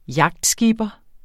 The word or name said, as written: yachtskipper